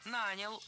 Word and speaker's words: нанял: Нанял — ударяем приставку.